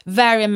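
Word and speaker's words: Mhairi: Jag tror att den som fick in mig på romance är en person som heter Varian McFarlane, men det stavas Mahiri.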